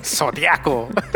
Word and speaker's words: zodiaco: Okay, so how do you say zodiac I, in Spanish? – Zodiac. – Yeah. Zodiac. – Zodiaco. – yeah, that's right.